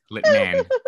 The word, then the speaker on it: Litman: It's Litman? Really good emphasis on the man. I appreciate that. Litman.